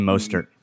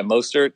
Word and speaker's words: Mostert: Raheem Mostert. – Yeah, Mostert.